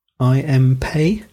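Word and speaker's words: I. M. Pei: So how do you pronounce his name? – I am Pei. – Pei, yeah, that's kind of what I thought.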